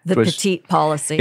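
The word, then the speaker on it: Petite: It's spelled P-E-T-I-T-E. I've always heard it pronounced in the Southern District as the Pettit policy, although it's spelled the way that you're saying it.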